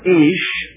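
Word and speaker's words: Ish (איש): And let me pronounce the Hebrew terms so that we'll get what is being said. She shall be called Isha because, verse 23, she was taken out of Ish.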